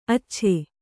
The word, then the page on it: ಅಚ್ಚಚ್ಚೆ: accacce